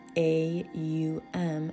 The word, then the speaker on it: AUM: When pronounced correctly, it is actually the three letters A-U-M and has four syllables. The A represents the waking state, U represents the dream state, and M denotes the state of deep sleep. The first syllable is the stretched out ah sound, which begins at the back of your throat, and as it is stretched out, you begin to sense your chest and solar plexus vibrating.